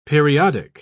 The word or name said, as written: periodic